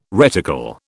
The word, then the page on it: reticle: British English